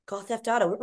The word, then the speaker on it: Gaw Theft Auto: Our Gaw Theft Auto shirt. Am I saying that right? Gaw Theft Auto. – Oh, yes.